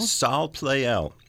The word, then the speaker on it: Salle Pleyel: So do I pronounce this Salle Pleyel? – Salle Pleyel.